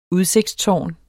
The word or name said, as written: udsigtstårn